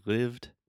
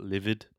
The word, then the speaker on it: lived: You just add a soft kind of td sound. Say lived, not livid.